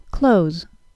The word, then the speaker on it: clothes: No, I'm not talking about the verb close, C-L-O-S-E, but the noun close, C-L-O-T-H-E-S, as in, she wants new clothes. You heard that right, though. I pronounced both of those words the same. Close, C-L-O-T-H-E-S, is a rather frequent word in American English.